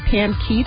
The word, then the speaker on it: Pam Keith: I'm also on Facebook at Pam Keith for U.S. Senate. And I am on Twitter at PamKeith2016. And my last name is spelled like the boy's first name, K-E-I-T-H.